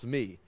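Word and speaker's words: me (מי): In the Hebrew, it is this right here, like this, M-I, pronounced me, and it means who.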